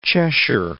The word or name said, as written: Cheshire